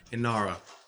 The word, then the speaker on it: Inara: Narg, Lucy Goodwitch, and Ignara. – Inara, the G is silent.